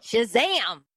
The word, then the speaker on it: Shazam: Shazam! – What's going on? – Say it correctly. There's an exclamation mark up there. You gotta say it with the oomph. You gotta be Shazam!